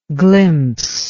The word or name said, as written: glimpser